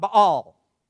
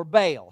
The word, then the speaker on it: Baal: See, the king represented the god Baal or Baal, however you want to pronounce it.